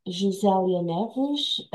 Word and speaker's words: Gisele Yaneves: I am Gisele Yaneves, the Portuguese way to say my name. Internationally, usually it's easier just to call me Josilia, or as I prefer, Jo.